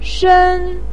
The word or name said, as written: shen1